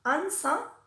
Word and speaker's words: answer: a veces también es muda y no se pronuncia, como por ejemplo en la palabra answer, o Google.